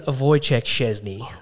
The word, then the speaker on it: Wojciech Szczesny: Uh, for the listeners at home, it is Wojciech Szczesny. Okay, that is how you pronounce his name, and I'm not even an Arsenal fan.